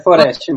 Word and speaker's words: Foreste: É Foreste mesmo, como você falou.